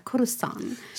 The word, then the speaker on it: Coruscant: Básicamente es eso Y nos vamos a Coruscant, o como pronuncian en inglés, que me encanta, Coruscant.